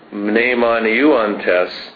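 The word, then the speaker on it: Mnemon euantes (μνημονεύοντες): Mnemon, Nate, notice that I pronounced the M there, the mu. – Ah. – Mnemon euantes,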